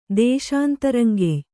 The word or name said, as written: ದೇಶಾಂತರಂಗೆಯ್